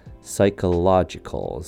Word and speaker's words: psychological: J has a short U sound and Col sounds like it's spelt C U L L psychological, psychological.